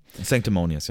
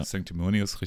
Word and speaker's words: Sanctimonious: Sanctimonious, ja. Sanctimonious, richtig.